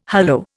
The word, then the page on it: hollo: Indian